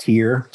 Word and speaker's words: Tyr: T-Y-R. How do you pronounce that brand? – It's pronounced tear, just like a tear that would come out of your eye.